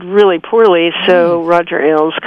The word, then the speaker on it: Ailes: And, I mean, for instance, is it Roger Ailes? Is that how you pronounce his last name? – Yes. Mm-hmm.